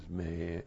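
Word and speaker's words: may (μή): And then point three, when you have with this the negative, which we do, the negative may, not your mule may, but this is may, M long E.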